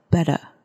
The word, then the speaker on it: better: 可是如果是在呃 RP 英式英文的 RP 腔调里面呢，它的后面的 R 是不发音的，所以你会听到 better。